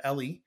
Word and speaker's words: Eli: And so this film follows basically most of this film takes place around these two children, Oscar and Ellie, Eli, E-L-I is her name. I think he pronounces it as Ellie, but they're both unbelievable.